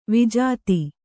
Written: ವಿಜಾತಿ

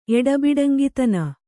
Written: ಎಡಬಿಡಂಗಿತನ

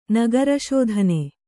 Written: ನಗರಶೋಧನೆ

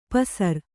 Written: ಪೌಸರ

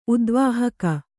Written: ಉದ್ವಾಹಿಕ

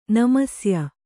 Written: ನಮಸ್ಯೆ

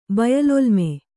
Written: ಬಯಲೊಲ್ಮೆ

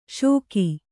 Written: ಷೋಕಿ

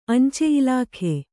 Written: ಅಂಚೆ

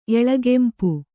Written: ಎಳೆಗೆಂಪು

